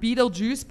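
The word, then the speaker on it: Betelgeuse: Apparently, so what happened was, if you see in the film, there's a part where they spell it B-E-T. – Yes. – B-E-E-T-L-E. G-U-E-S-E, I think. And that basically is, so you pronounce it Beetlejuice, but it's a constellation of stars.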